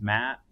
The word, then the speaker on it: Matt: It didn't help that my name was Matt, which rhymes with fat.